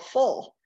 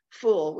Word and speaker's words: Ful: And I asked the chef what it was, and it's called Ful, I think is how it's pronounced, F-U-L.